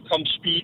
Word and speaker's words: Compeed: Du skal bruge Compete eller Compeed. Jeg kan ikke huske præcis udtalen. – Compeed, Compeed.